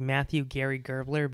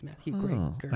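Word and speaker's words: Matthew Gray Gubler: And then like Matthew Greg Gerbler. Is that how you say it? – I don't know. – I always thought it was like Matthew Gary Gerbler, but it's Gray. It's Matthew Gray Gerbler.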